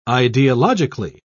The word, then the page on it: ideologically: British English